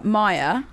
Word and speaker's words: Mair: Pronounced like dryer, Maya. – But spelt like hair with an M.